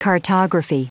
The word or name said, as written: cartography